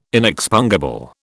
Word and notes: inexpungible: British English

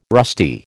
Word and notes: rusty: American English

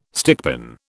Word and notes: stickpin: British English

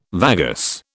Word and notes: vagus: British English

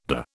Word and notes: dah: British English

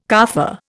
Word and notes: carfare: Indian

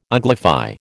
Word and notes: uglify: American English

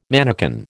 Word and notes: manakin: American English

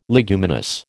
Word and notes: leguminous: American English

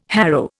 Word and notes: harrow: Indian

